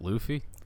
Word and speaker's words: Luffy: Is that how you pronounce it? Luffy? – Luffy. – Yes.